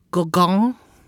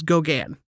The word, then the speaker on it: Gauguin: And the other guy, he's French, and so it would be more like Paul Gauguin or Gauguin. But I'm going to say it the American way, which is Gauguin.